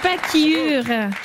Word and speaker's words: pachyure: Le pacure, qui s'écrit P-A-C-H-Y-U-R-E, si vous voulez le chercher sur Internet. Le pacure étrusque, oui, faites gaffe, est le plus léger mammifère existant.